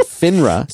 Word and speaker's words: FINRA: I was told I should pronounce it FINRA.